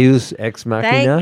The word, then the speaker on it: Deus Ex Machina: Deus Ex Machina.